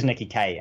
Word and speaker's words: Nikki Kaye: It is Nikki Kaye, and it's just my bad for not actually having said it out loud ever.